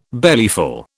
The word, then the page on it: bellyful: British English